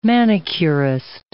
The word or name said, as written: manicurist